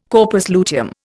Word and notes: corpus luteum: Indian

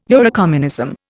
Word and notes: Eurocommunism: Indian

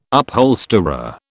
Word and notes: upholsterer: British English

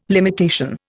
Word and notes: limitation: Indian

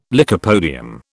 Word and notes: lycopodium: British English

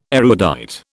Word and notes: erudite: British English

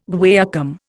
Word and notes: guaiacum: Indian

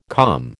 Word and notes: calm: American English

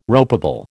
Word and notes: ropeable: American English

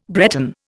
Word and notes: Breton: Indian

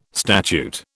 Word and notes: statute: British English

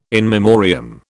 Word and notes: in memoriam: British English

